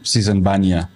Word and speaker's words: Sisan Baniya: Sisan Baniya.